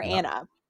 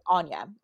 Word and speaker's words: Anya: Anya or Anna? How would you say it? Anya. Okay, that's what I thought.